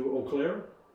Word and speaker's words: Eau Claire: And so from here, I head over to Eau Claire. I think that's how you pronounce it. – Yes, you do. Nailed it.